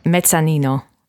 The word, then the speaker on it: Mezzanino: How do you say it in Italian, by the way? – Mezzanino.